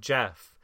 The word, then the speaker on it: Gef: According to the Irvings, a creature named Jeff, G-E-F, because apparently Jeff is pronounced the same way as Jif.